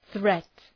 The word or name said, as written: threat